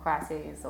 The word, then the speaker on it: crises: So when I was thinking about this topic of existential crises, I started thinking of crises, or I don't know how to say it. – crises. Crises for plural, crisis for singular.